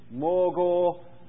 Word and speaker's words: Magor: Magor, pronounced Morgor Misabib.